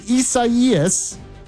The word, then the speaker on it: Isaias: And number one, Hurricane Isaias. And yes, that is how you pronounce it, believe it or not.